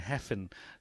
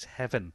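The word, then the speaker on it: Hefin: Uh, I think we've been going Heffen, so it is Heaven. Owen. So, uh, just a note for our commentators uh, out and around the place we, we get the correct Welsh pronunciation of Mr. Owen as he is racing here.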